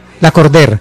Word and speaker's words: Lacordaire: Lacordaire. – Pero como en francés, como Lacordaire. Sí. Una cosa así. – Es que es un nombre francés. Sí, se escribe Lacordaire, pero se pronuncia Lacordaire.